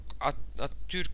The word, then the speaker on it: Atatürk: "Korkarım Atatürk, Atatürk, doğru telaffuz ettim mi yeni soyadını?" – "Ettin de Gazi'nin önümüzdeki günlerde at binmeye vakti de hali de olmayacak," dedi Gerhard.